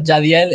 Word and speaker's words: Jahdiel: Yeah, and it's cool because like uh, Jahdiel este, no se escribe con, con Y, es J-H.